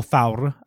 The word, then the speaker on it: Thor: So in modern Icelandic, which is largely unchanged from medieval Icelandic, you would pronounce Thor Thaur and Loki Lauki.